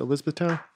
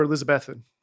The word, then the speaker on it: Elizabethtown: And that's how you pronounce it, Elizabethtown? – Yeah. – I never knew it. I never knew it was that or Elizabethan.